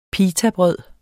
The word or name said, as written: pitabrød